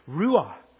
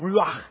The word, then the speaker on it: Ruah: Our next word, Ruah. Not Ruach. That's spirit or wind. Ruah. No at the end of that one.